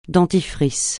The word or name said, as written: dentifrice